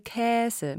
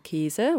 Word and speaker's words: Käse: Also tatsächlich würde in dem Aussprachewörterbuch noch stehen Käse mit Ä. Das entspricht aber nicht der sprecherischen Wirklichkeit, sage ich mal, in den Medien. Die meisten oder viele Mediensprecher sagen Käse oder Universität, Mädchen.